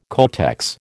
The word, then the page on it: codex: American English